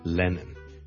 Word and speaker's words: Lennon: 第一个字咧就系、是、人名嚟嘅，John Lennon。 John Lennon。 John Lennon 一个英国嘅好出名嘅歌手作曲家啦。 Lennon。 L E N-N-O-N 第一個是 L 音所以不要將它讀成 N 音不是 Lennon 是 Lennon Len Len 後面那個才是 N 音 Lennon 還有兩個 N 音 Lennon 第一個 L Lennon John Lennon 大家練一練 John 當然是 N 音味又更加搞亂 John Lennon，無論你讀得幾快都好，都唔可以變咗 John Lennon，唔係 John Lennon，一定係 John Lennon，John Lennon，John Lennon。